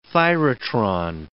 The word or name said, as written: thyratron